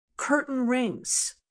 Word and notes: curtain rings: American English

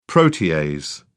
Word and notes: protease: British English

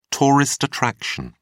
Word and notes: tourist attraction: British English

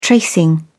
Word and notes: tracing: British English

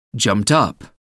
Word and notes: jumped up: American English

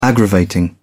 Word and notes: aggravating: British English

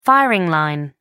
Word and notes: firing line: American English

